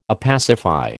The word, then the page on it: opacify: American English